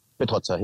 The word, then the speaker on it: Petrozza: Okay, so your name is pronounced Mille Petrozza. – Petrozza, yeah. – Petrozza, oh, absolutely.